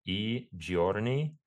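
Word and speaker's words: I Giorni: it's called E Giorni. It's in Italian, so it's just I, pronounced E, and then Giorni.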